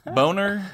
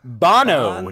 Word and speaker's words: Bono: Boner? – Bono.